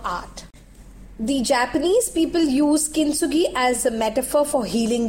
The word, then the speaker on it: Kintsugi: Have you all heard about Kintsugi? It is spelled as K-I-N-T-S-U-G-I, Kintsugi.